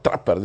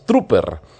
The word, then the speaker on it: Trooper: Trapper dicen algunos, pero es Trooper. Así se escribe, así se lee.